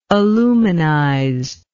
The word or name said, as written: aluminization